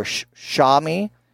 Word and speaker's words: Xiaomi: It's spelled X-I-A-O-M-I, and I call it Xiaomi or Xiaomi. Xiaomi, that's what I'm going to go with, Xiaomi.